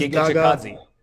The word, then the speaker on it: Giga Chikadze: Giga Chikadze.